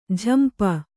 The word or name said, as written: ಝಂಪ